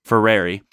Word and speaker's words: Freire: Freire.